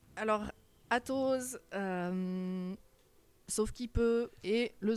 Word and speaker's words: Atoz: Alors revenons un petit peu sur, déjà on va commencer par Atos, les éditions Atos. Qu'est-ce donc ce mot un petit peu bizarre, Atos, A-T-O-Z ? – À la base c'était A2Z et puis évidemment la prononciation anglophone, ben, c'est devenu Atos.